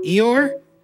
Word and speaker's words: Eeyore: E-E-Y-O-R-E. Eeyore?